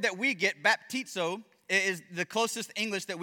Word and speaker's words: baptizo: But if you notice, the letters look pretty close, but the original Greek word would have been pronounced baptizo.